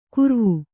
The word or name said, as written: ಕೂರ್ವು